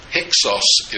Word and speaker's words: Hyksos: Take that first syllable. It sounds like Hicks, right? Hyksos is what they were called.